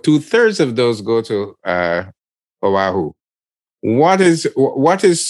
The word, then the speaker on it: Oahu: I read where Oahu, I hope I'm pronouncing it correctly, O-A-H-U. – Got it right. You said that.